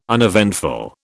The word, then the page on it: uneventful: British English